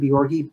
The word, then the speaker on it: Bjorge: Yes, on YouTube, just put in the search, put the words Liberation Theurgy or my name, Nathan Bjorge, B-J-O-R-G-E, into the search button in YouTube, and my page will come up.